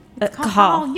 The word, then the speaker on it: Cajal: So is it Cajal or Cajal? – Cajal. You know Cajal.